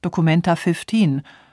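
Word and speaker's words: documenta fifteen: Sie wird englisch ausgesprochen, Documenta 15, und sie wird von einem zehnköpfigen Team kuratiert.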